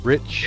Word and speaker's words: Rich: Uh, so tonight we got Mac, uh, Don McAlhany, uh, Jim Kleeman, and Rick Synowiec. Did I say your name right? – It's Rich Synowiec. – Rich yes.